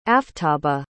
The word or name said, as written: aftaba